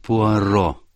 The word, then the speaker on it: Пуаро: Мсье Пуаро. Пуаро, мадам, — поправил ее он, сделав ударение на последний гласный.